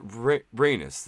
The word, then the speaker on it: Rhaenys: The only good person and my heroes of the show are Princess Rhaenys. I always feel stupid saying her name because I don't feel like I'm saying it right, but Rhaenys, that's how you say her name, correct? – I think so.